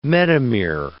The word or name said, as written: metamere